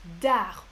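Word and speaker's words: Arz: Alors, la première île que j'ai visitée, c'est l'île d'Ar. On ne prononce pas le Z en breton.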